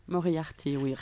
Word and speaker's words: Moriarty: Well, we've got a first track for today by Moriarty, who is not pronounced Moriarty if you're French. How would you say it? – Moriarty, oui.